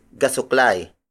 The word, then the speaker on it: gasuklay: The next shape is gasuklay. Gasuklay. Gasuklay. Gasuklay is spelled as G-A-S-U-K-L-A-Y.